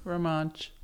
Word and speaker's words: Romansh: Romansh.